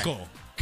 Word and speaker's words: Keuchel: Oh, we just butchered Kuchel's name. Was it Kuchel? – It's Keuchel. – Keuchel. Oh, it is Keuchel. You're right, it is Keuchel. – I'm like, there is no I in there at all whatsoever.